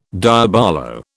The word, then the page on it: diabolo: British English